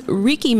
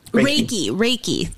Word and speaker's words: Reiki: Because so the other thing that I had read on your website was Reiki Master. Am I saying it properly? – Reiki. – Sorry. Reiki, can you explain to us what that is? – Sure.